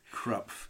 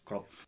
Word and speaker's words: Krapf: Can I just say, for those that want to know more, as I found out, it looks as if it says Krapf, K-R-A-P-F. But as I never had a German lesson in my life, it's pronounced Krupf. – Yes, I'm glad that my German friends were able to confirm that it's actually pronounced Krupf.